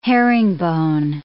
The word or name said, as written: herringbone